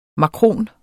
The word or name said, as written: makron